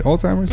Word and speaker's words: Alzheimer's: Alzheimer's? – Mm-hmm.